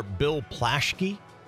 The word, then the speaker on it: Bill Plaschke: Do you know the sports writer Bill Plaschke? – Yes. – Is that how you say his name? – Yes, it is.